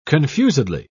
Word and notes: confusedly: British English

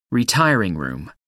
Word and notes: retiring room: American English

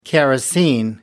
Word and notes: kerosene: American English

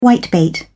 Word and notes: whitebait: British English

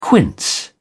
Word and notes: quince: British English

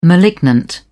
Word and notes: malignant: British English

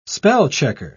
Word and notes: spell-checker: British English

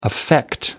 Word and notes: affect: American English

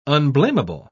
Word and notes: unblamable: British English

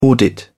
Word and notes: audit: British English

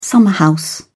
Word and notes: summerhouse: British English

